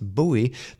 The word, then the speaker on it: buoy: If the B-O-U-Y that bobs in the water is pronounced buoy, then why don't we say buoyant? Why is B-O-U-Y buoy in the first place, and why not a bow-y?